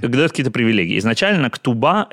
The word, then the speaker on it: ктуба: А, а, э, и это можно произносить так же, как «ксуба» или как «ксиба».